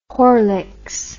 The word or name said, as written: Horlicks